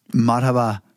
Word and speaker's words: Marhaba: Marhaba.